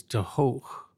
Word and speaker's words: de Hoog: I think the proper pronunciation is de Hoog in Dutch, but in Ireland he's known as Mickey de Hoog. D-E, new word, H-O-O-G.